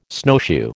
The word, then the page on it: snowshoe: American English